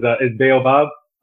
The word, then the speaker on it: Baobab: So, first, let me correct the pronunciation, which is Baobab.